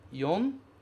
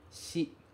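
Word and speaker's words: yon (四): Ele tem duas pronúncias principais, yon e shi.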